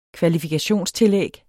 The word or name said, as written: kvalifikationstillæg